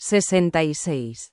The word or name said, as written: 66